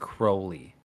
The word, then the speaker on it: Crowley: He rhymed his own name with Holy, which implies Crowley. That his last name is pronounced Crowley.